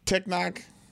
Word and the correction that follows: Tenoch: Villa. That's not how you say his name. Tenoch, Villa.